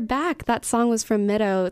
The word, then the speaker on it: Mideau: Well, the band name is called Mido, and that's spelled mid, E-A-U, like the French word for water, M-I-D-E-A-U.